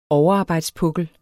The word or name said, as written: overarbejdspukkel